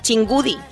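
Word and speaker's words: Txingudi: Chingudi una zona protegida medioambientalmente y con unas excelentes playas. – Muy bien. Sabéis que la CH ellos lo escriben TH. – TH, sí.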